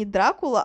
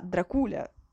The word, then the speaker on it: Дракула: И недавно я узнала, что правильно говорить не Дракула, а Дракуля.